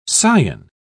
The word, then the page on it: cion: British English